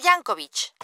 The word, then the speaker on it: Jancovich: Laura Jankovic. – Laura, ¿cómo se escribe el apellido? – J-A-N-C-O-V-I-C-H.